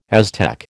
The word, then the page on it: Aztec: American English